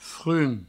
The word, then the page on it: frum: frun